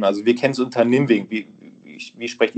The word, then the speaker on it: Nimwegen: Du bist 1971 in Nimwegen, wenn ich es richtig ausspreche, geboren worden. – Ja, also auf Deutsch ist das richtig ausgesprochen, ja.